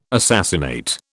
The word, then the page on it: assassinate: British English